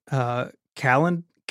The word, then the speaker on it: Calendly: The only one I use sometimes is called Calendly. Calendly? Is that how you say it? – Yeah.